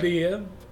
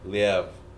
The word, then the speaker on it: Liev: It was like, Liev? – Liev. And I was like, oh. – Yeah. – Like Kiev? Chicken Kiev? – Well, that was a really good documentary.